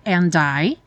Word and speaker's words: and I: And I. Aqui também não é comum pronunciar o D, você até poderia falar and I, mas é mais comum and I, já juntado N.